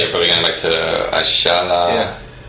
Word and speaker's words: Ayala: Ashala, for the benefit of Anglophones listening, is the proper pronunciation of the player you'll all know as Roberto Ayala, the Valencia centre-back and national team centre-back for a long time. Argentines pronounce Y as funny, so that's why he's called that.